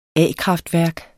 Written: a-kraftværk

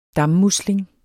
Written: dammusling

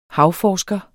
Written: havforsker

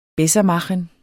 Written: bessermachen

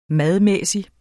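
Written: MADMÆSSIG

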